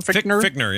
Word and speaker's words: Fichtner: And that dude, William Fichtner? – Fichtner, yeah.